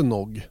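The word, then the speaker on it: Noghès: Uttalas det Nog? Mm. Okej, okay, bra. För det har jag alltid undrat. Jag kallar den Venouge och allt möjligt franskt. Att man bara går på magkänn.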